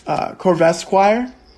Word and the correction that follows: Corvisquire: No, Corvisquire. That's his name.